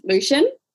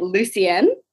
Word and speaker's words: Lucian: Like one of the characters I got told was pronounced Lucian, but I was pronouncing it Lucien, but that's because it has my name and then an N on the end.